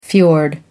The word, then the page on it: fiord: American English